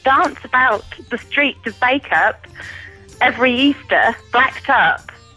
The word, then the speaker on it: Bacup: Backup coconutters. – Yeah, bake-up. – Bake-up, is that how you pronounce it? – Yeah. – It's, okay, listen, everyone, if you're at home, go and type this into Google. Bake-up, B-A-C-U-P, coconutters.